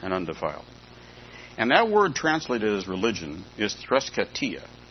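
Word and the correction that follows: threskeia: I'll spell it for you. T-H-R-E-S. T-H-R-E-S-K-E-I-A. Threskia. I said it wrong. Threskia. That's religion, threskia.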